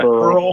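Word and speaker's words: Beryl: And for those listening, Eric is saying the word barrel, but it's spelled B, Bravo, Echo, Romeo, Yankee, Lima. – Barrel. – No, you're not. Burl. You're not pronouncing it wrong. It's just a homophone or whatever you call them.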